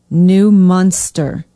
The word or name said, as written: new munster